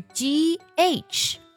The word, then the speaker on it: ghost: 下面一个单词， ghost，鬼或是幽灵的意思，它的拼写当中呢是 ghost，但是 h 不发音哦，我们要把它读作 ghost，而不是 ghost，不是这样去读的。